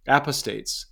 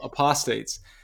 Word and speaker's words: apostates: Apostates. I always put the accent on the wrong syllable. Apostates.